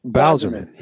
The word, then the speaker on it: Bowserman: Wait, how do you say your last name? Oh my God, I'm like an idiot. – Bowserman. – Yeah. I was going to call you Bowerman for a second there.